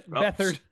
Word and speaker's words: Beathard: I mean, with a full week of practice in this offense, you know, in this system, one that, you know, CJ Beathard, did I say it right that time? I think nope. – I did. – Beathard.